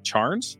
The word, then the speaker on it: Charnes: Is it Charnes? – Nailed it.